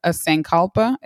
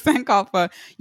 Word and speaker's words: Sankalpa: So, and I also know that you know, in your bio, you have, excuse me if I pronounce this wrong, but a Sankalpa. Is that how you pronounce it? – Yep. That's good. Yes. – Okay. Sankalpa.